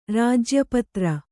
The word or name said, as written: ರಾಜ್ಯಪತ್ರ